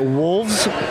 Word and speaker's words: wolves: Do you pronounce the V? – I do. Wolves. – Yes, you should.